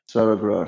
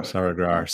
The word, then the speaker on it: Saraghrar: Yeah, Saraghrar. – Saraghrar.